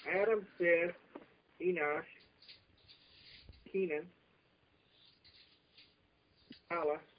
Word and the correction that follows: Mahalalel: How to pronounce that one? Jared, Enosh... – That's Mahalalel. – Mahalalel? – Mahalalel. Mahalalel. That's his name.